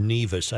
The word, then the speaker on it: Nevis: The chief offsite correspondent wants me to tell you it's pronounced Nevis. I don't know what you said. – I said Nevis. Sorry. – That's Nevis. – Nevis. Okay. – Hey, thanks for knowing that, offsite dorky man. Good. – Mm-hmm. No, it's Nevis. It's Nevis. We're fact-based. – N-E-V-I-S. That's a weird pronunciation. You think it'd have two E's.